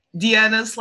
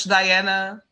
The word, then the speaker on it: Diana: Anyway, hi, I'm Diana / Diana, however you want to call me.